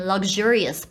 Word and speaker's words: luxurious: 可是很奇妙的是，当它变成形容词的时候，连英国人都会把它念成有声化的方式，就是它的形容词会比较长一点，就是叫 luxurious，luxurious。你会发现 lug 有声，jurious。不是 serious，是 jurious，所以呢，这个字也是很妙。真正的母语人士会念 luxurious，不是 luxurious。